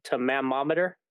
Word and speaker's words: tomatometer: And then Rotten Tomatoes, and I always have problems pronouncing this, the tomatometer, whatever.